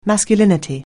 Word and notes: masculinity: anglais

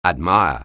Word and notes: admire: anglais

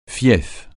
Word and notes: fief: francais

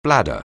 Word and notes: bladder: anglais

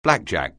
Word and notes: blackjack: anglais

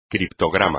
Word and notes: criptograma: espagnol

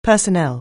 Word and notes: personnel: anglais

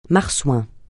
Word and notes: marsouin: francais

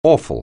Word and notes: awful: anglais

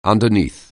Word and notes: underneath: anglais

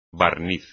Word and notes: barniz: espagnol

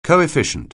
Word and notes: coefficient: anglais

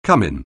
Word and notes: cumin: anglais

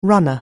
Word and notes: runner: anglais

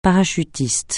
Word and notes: parachutiste: francais

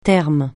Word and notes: terme: francais

